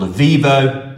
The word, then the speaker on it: Eviivo: So we use a company called Avivo, that's spelled E-V-I-I-V-O.